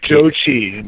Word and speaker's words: Zhou Qi: You've got is it Zhu Qi? How do you say that? The Chinese guy that you have grade Zhou Qi.